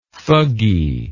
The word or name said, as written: thuggee